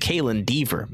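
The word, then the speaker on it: Kaitlyn Dever: Now, I apologize for the mispronunciation. It's Kaylin Dever. My bad. I don't know why I added an N.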